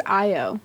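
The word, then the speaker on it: Ayo: Uh, it's kind of like a flashback six years back where Bucky and Oyo, if that's how you say it. Um, I think it was Io.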